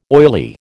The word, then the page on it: oily: American English